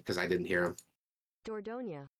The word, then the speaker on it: Dordogne: Dordogne? How the fuck do you pronounce that? Hold on. – Ask Google. – It's French, according to YouTube. – Dordogne.